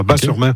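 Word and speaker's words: Batz-sur-Mer: Et surtout, la crêperie s'appelle le Dervin à Bas-sur-Mer, qui font les meilleures crêpes de la domaine. – Oui, Bat, moi, on prononce Bas, le T ne se prononce pas, Bas-sur-Mer.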